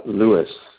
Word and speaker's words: Luis: Lewis, L-U-I-S.